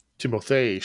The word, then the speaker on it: Timothée: Emma Watson, Laura Dern, Timothy Chalamet. – Timothée Chalamet. – Oh. I didn't put that emphasis on there.